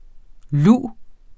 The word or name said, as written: luv